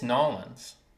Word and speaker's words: New Orleans: See, people always make fun of me for saying New Orleans. – It's Nolens.